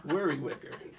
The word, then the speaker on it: Worricker: W-O-R-R-I-C-K-E-R. – It's like the word worry minus the Y, and then wicker minus the W. – Worry wicker.